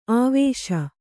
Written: ಅವಶ

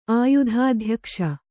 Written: ಆಯುಧಾಧ್ಯಕ್ಷ

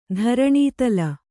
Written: ಧರಣೀತಲ

